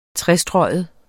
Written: trestreget

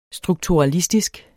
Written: strukturalistisk

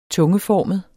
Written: tungeformet

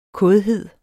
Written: kådhed

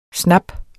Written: snap

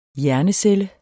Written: hjernecelle

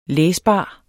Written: læsbar

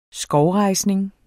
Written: skovrejsning

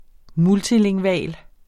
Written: multilingval

